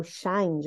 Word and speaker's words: Shein: O Pedro falou de uma tal de Shein, e eu imagino que é assim que o pessoal fala lá nos Estados Unidos, e é, o jeito oficial da marca de pronunciar a sua marca. Mas aqui no Brasil, a gente conhece a marca como Shein ou Shine, já ouvi algumas pessoas também falando Shine, né?